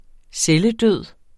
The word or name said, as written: celledød